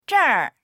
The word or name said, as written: zher4